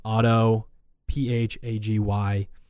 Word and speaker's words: autophagy: It's spelled auto, P-H-A-G-Y. It's pronounced autophagy.